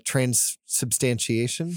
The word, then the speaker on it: transubstantiation: It's certainly not transubstantiation.